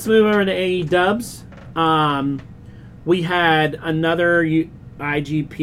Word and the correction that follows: IWGP: United States title defense. – IWGP United States.